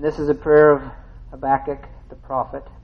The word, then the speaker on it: Habakkuk: Habakkuk 3, I'm going to begin with verse 1 in chapter 3 of, is it Habakkuk or Habakkuk? Habakkuk.